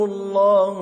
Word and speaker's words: الله: So, في الكتاب أولئك يلعنهم يلعنهم يلعنهم يلعنهم الله. Allah is pronounced heavily. Why? Because dhamma sound is before. If there is a kasra, you pronounce it lightly.